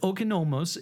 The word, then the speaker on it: oikonomos: You speak a lot in your in the book, and heard you other places talk about your work as an oikonomos. Am I saying that right, first off? – Oikonomos, you okay. Got it.